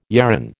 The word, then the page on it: yarran: American English